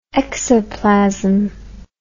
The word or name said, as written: exoplasm